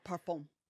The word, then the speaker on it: parfum: Parfum? Is that perfume? – Yeah, parfum.